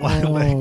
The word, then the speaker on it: Lilac: You still said it wrong. – Shut up. – Is it Lilac? – No. No, it's not Lilac. – Oh, love you a long time. – You know what the flower is? – A lilac? – Yeah, a lilac. – A lilac. – There you go. That's how you say it.